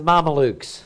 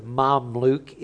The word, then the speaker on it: Mamluk: That's the Mamelukes is what it is. The Mamluk is the way it's actually pronounced.